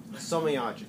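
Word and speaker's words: Somayaji: Soma Yaji. Soma Yaji. Yeah. Yeah. No. So the key thing is all the vowels are pronounced, but the J is pronounced as a G.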